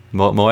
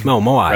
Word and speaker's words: Moai: And it's a Konami game, so you fight a Moe? Is that how you say it? Easter Island guy. – Moai. – Right, Moai.